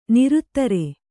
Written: ನಿರುತ್ತರೆ

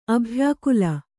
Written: ಅಭ್ಯಾಕುಲ

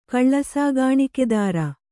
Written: ಕಳ್ಳಸಾಗಾಣಿಕೆದಾರ

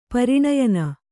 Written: ಪ್ರಣಯಿನಿ

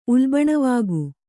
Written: ಉಲ್ಬಣವಾಗು

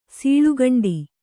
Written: ಸೀಳುಕಂಡಿ

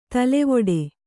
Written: ತಲೆವೊಡೆ

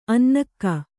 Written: ಅನ್ನೆಗ

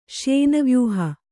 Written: ಶ್ಯೇನವ್ಯೂಹ

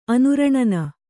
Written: ಅನುರಣನ

